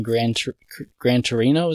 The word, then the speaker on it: Gran Torino: So if you have any thoughts on Gran Torino, is that how it's said? – That's correct.